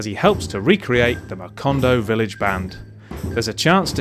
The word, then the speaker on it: Macondo: Macondo Village Band, yeah.